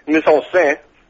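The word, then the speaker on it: mise-en-scène: Mise-en-scene? – Mise-en-scene that is known variously as Pastor Cocaine or Comptroller of Jonestown.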